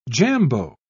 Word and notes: jambeau: British English

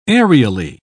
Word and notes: aerially: British English

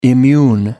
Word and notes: immune: American English

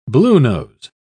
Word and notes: bluenose: British English

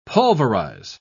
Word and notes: pulverise: British English

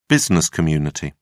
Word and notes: business community: British English

